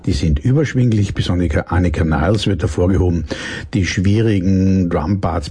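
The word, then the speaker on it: Nilles: Jetzt haben sich die beiden verbliebenen Kanadier, der Gitarrist Alex Lifeson und Sänger und Bassist Gary Lee, mit der deutschen Schlagzeugerin Annika Niles oder Niles, wie die Amerikaner es aussprechen, und dem US-Amerikaner Lauren Gold an den Keyboard verstärkt und am 7.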